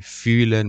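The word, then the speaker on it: fühlen: برای نمونه واژه فلن میشه پر کردن، ولی فیلن میشه حس کردن.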